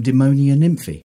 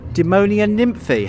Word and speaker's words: Demonia Nymphi: We were also lucky enough to get some time to chat with Spiros and Evie from Demonia Nymphi.